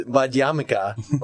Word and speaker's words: Madhyamika: Okay, Madhyamika is how I was initially pronouncing it. The same video was Madhyamika, like Hanukkah, to make me think of that.